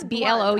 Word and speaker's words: Blount: It's Blount or Blount, depending on how you say it. Because Garrett Blount, who plays for yes. – the, they say Blunt when you say it fast, yeah.